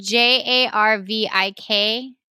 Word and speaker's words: Jarvik: Is the name said Jarvis and spelled Jarvis? – It's spelled J A R V I K Jarvik. Okay. Yeah.